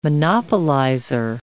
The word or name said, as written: monopolizer